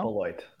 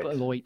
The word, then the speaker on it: Beloit: Beloit. Beloit. – Beloit.